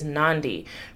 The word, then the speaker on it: Nandi: In case you've ever wondered how to say my name, it is Nandi, rhymes with Gandhi.